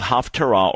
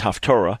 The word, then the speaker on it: Haftarah: And then there are portions called Haftarah, or normally pronounced Haftorah.